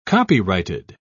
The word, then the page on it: copyrighted: British English